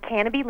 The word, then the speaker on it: Canobie: It's pronounced Canobie Lake Park.